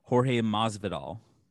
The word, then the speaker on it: Jorge Masvidal: It's Jorge Masvidal.